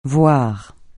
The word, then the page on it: voir: francais